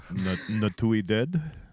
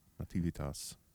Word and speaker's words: Nativitas: Natuided. – Okay. – Nativitas.